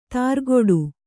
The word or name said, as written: ತುರುಕುಡಿ